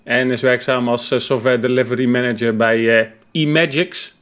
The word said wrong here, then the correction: eMagix: Zeg ik het goed, Samet? – Nou, niet helemaal. Het is eMagix.